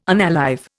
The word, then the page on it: unalive: Indian